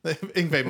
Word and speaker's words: Yngwie: Oh, Wingy Malmstein? – Yngwie Malmstein.